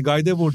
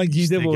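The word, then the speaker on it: Guy Debord: Şey telaffuz sitelerine baktım Süper bir, bir şaşırdım yani Gaydebor diyecektim ama Gidebor işte diye.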